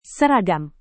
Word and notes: seragam: indonesian

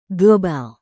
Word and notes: global: Indonesian

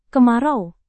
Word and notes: kemarau: indonesian

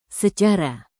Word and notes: secara: indonesian

